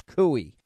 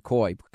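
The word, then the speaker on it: Coie: I think the proper pronunciation is Cooey. I said Coy. Cooey.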